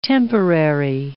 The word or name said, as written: temporary